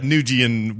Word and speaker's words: Nguyen: I thought it's pronounced Nguyen. – It is Nguyen.